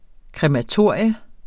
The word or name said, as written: krematorium